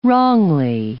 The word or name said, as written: wrongly